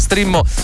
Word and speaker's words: Streammo: Lo ricordo che si pronuncia Strimmo, ma si scrive Streammo.